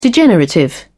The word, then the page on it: degenerative: British English